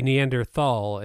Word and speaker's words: Neanderthal: You when I say Neanderthal and you say Neanderthal. – It's generally pronounced Neanderthal because it's a German word.